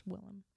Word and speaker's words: Willem: Well, in Dutch, Willem. – Oh, so it's spelled William, but that's how you pronounce it? – No, it's W-I-L-L-E-M.